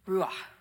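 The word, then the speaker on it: ruach (רוח): The Spirit was originally translated as feminine in the Hebrew, ruach.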